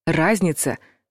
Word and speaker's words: разница: Мнения разнятся, цифры тоже разнятся, а ударение падает на первый слог. Разница.